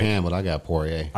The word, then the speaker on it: Poirier: You got Oliveira versus Poirier. Is that how you say it? Poirier.